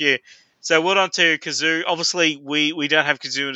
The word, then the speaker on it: Cazoo: I hope I'm saying that properly, but it's Cazoo. Yeah, so that makes sense. Car and Zoo.